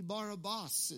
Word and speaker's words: Barabbas: Really, Barabbas is how it's pronounced.